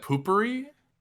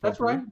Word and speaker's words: potpourri: Wait, isn't that poopery? – No, it's potpourri. I think it's pronounced potpourri.